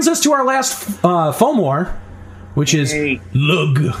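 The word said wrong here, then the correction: Lugh: It's pronounced Lou, actually, modern Irish.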